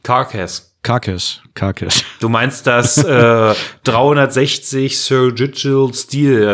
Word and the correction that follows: Surgical Steel: also wie auch man das aussprechen mag. – Ja, genau. Ja. Und das als Kette, Surgical Steel. – Surgical, ja, genau, danke.